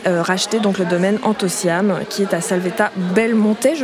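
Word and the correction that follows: La Salvetat-Belmontet: je prononce bien Oui, c'est la Salvetta Belmonte.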